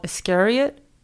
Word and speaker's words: Iscariot: He exhibited his work, his first art show called Iscariot. Am I pronouncing that right? – Mm-hmm, like Judas Iscariot? – Yes.